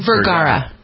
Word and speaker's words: Vergara: Is that how you pronounce her name? – Vergara. – Vergara. Mm-hmm. Vergara.